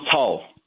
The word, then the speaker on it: Cao: Is it Pastor Cao? – Yeah, Pastor John Cao.